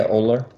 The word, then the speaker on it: Ohler: All right, so I'm here with Norman Ohler. That's how you pronounce it? – Yeah, Ohler.